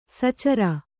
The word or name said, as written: ಸಚರ